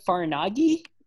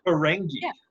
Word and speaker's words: Ferengi: The Farnagi? – Ferengi.